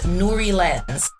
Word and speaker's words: Nuri-Lens: Nuri-Lens. We are on Instagram. We're on Facebook. We are on Pinterest. We are on LinkedIn. Nuri-Lens.